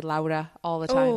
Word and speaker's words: Laura: Not Lara. Laura. Yeah. As my, I had an ex coworker who I mistakenly pronounced her name Lara, and she's like, it's not Lara, it's Laura.